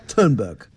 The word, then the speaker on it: Thunberg: How you say it. – Yeah, well, that's how she says it. – That must be the right way. Yeah. Greta Thunberg.